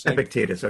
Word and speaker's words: Epictetus: Epictetus.